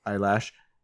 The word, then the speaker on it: Eilish: The next one reminds me of Billy Eilish. Eilish? – William Eyelash. – Eyelash. Eilish.